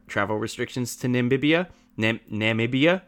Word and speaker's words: Namibia: He wanted to shoot in Namibia, which is a okay.